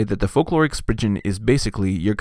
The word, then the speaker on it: Spriggan: Now, I have always called this creature a Spriggan. It has two G's, and that is a hard G sound, typically, in English. Not absolutely always, because there's no absolutely always in English. But apparently this is a Cornish word, and it is traditionally pronounced Spridgen.